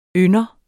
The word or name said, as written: ynder